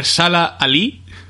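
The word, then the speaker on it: Mahershala Ali: Sala Ali por Moonlight.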